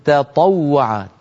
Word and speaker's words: تطوع: مر معنا في هذه الصفحة قوله تعالى في الآية 84 بعد 100: فمن تطوع تطا، هنا التقى في النطق تاء وطاء، والتاء والطاء من المخرج نفسه، فإن لم ننتبه قد تتفخم التاء لمجاورتها للطاء. الفم أسهل عليه أن يقول تطوع، فمن تطوع يجد ذلك سهلا، لكن علينا أن نرقق التاء ونفخم الطاء هكذا: فمن تطوع خيرا.